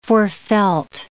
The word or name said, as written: forefelt